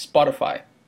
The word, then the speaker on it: Spotify: Que eu nem sei se essa é a pronúncia correta em português, mas Spotify. – É, isso mesmo.